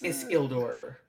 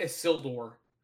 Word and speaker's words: Isildur: It's Isildur. Like there's a space, like, but it's not Isildur.